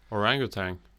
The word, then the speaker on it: orangutan: How do you say orangutan? – Orangutan. – Yeah, see, apparently there's no G in it at the end. – Orangutan. – Orangutan. Without the G.